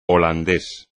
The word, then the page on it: holandés: espagnol